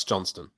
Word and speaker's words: Johnston: Alex Johnson. Why does it sound weird? Am I pronouncing his last name wrong? – Alex Johnston.